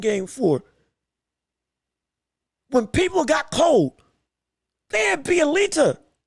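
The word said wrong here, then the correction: Bjelica: I believe I'm saying his name right. Excuse me if I'm not. Apologize if I'm not. They had Bielita, the white guy. So again, I apologize. I can't say his name right. So sorry. I believe it's Bielica. Bielica, that's it.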